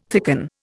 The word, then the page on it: thicken: Indian